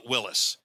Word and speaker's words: Willys: I always called it Willys until someone corrected me and said call it Willis.